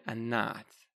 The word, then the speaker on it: Anath: Although, there is some evidence to suggest that the Canaanites worshipped Asherah and Anath, or Anath. Depends how you pronounce that name.